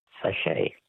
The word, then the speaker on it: sachet: Words that end with a silent T. Which word for a small sealed pouch literally means little sack in French? – Sachet. – A sachet. S-A-C-H-E-T. Well,